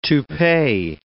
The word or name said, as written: toupee